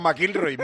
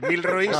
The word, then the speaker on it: McIlroy: McIlroy. – McIlroy. Bueno, como se llame, como tú lo digas. – No, es que se llama McIlroy. Milroy será su señora, yo qué sé.